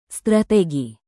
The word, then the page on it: strategi: Indonesian